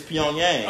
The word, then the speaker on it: Pyongyang: It's Pyongyang. – Oh, for North Korea, maybe like, Pyongyang.